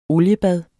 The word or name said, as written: oliebad